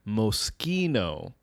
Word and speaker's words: Moschino: So the brand that a lot of like celebrities wear that you would think it's pronounced Moschino is actually pronounced Moschino.